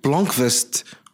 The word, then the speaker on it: Blomkvist: But Michael Blomkvist, I think I said it properly.